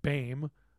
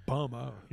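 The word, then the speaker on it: Böhme: We all grew up saying BAME, but it's spelled B-O-H-M-E, so it's really like BOMA, you know, right?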